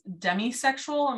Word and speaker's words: demisexual: So we found out like an episode or two ago that Carrie is demisexual.